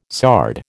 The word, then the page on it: sard: American English